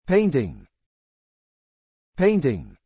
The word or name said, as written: painting